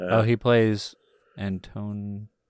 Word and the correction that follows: Antoninus: Sorry. Antoninus. I was like, I know I've heard the name. Why am I saying it wrong?